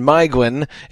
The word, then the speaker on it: Maeglin: that is Maedhros and Maeglin, both of which should be I and not A.